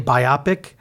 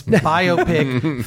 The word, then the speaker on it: biopic: You notice lately how many people say biopic? Idiots. It's biopic. Thank you for your correct pronunciation of a very simple word. Yeah. But no, biography picture. – Right. Yes. That's literally where it's from.